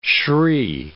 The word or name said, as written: shri